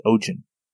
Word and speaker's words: Ogen: And then the Vikinger, is Ogen. It's O-G-E-N. Kind of like Odin, but with a G.